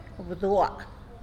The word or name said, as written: bdua